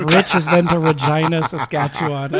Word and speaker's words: Regina: It's pronounced Regina. – Is it Regina? – Yeah, it's a Latin word.